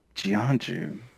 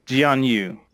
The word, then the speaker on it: Jianyu: Jianju, huh? Jianyu. It's spelled Jianyu. So that's why Jianyu, Jianyu, but not Jianju, Jianzu.